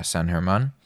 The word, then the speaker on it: San Germán: It's spelled like San German, but yeah, San Germán in Spanish.